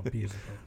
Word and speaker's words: Beelzebub: I wonder why you, like, pronounce the L-E. – Because I'm pretty sure the actual name of, like, the creature is Beelzebub. – It should be Beelzebub. – No, it's B-E-L or B-E-E-L-Z-E-B-U-B. They look at that pronunciation. – Oh, Beelzebub. – Yeah, Beelzebub.